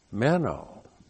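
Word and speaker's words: Meno (μένω): Meno. M-E-N long O. Meno.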